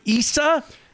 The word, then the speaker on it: Issa: A guy by the name of Jabari Issa. – That name is that the name? Am I pronouncing it right? – I think you are. Issa. Yeah. Jabari Issa.